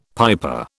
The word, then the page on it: piper: British English